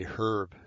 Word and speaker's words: herb: Actually, it's cannabis ruderalis herb, H-E-R-B, herb. My dad used to say herb, just to drive me crazy. I know it's a, but dad, the H is silent.